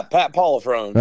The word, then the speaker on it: Pat Pollifrone: Yeah, Pat Pollifrone.